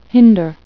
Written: hinder

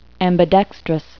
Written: ambidextrous

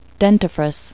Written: dentifrice

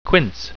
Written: quince

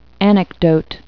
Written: anecdote

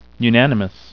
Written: unanimous

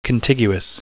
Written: contiguous

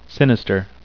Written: sinister

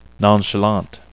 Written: nonchalant

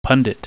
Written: pundit

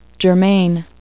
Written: germane

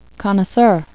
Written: connoisseur

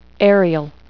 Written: aerial

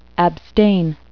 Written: abstain

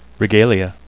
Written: regalia

